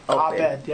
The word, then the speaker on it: op-ed: Op-ed? – Op-ed, yep. – Is that the way you pronounce it? – That's the way we pronounce it, op-ed.